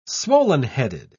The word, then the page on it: swollen-headed: British English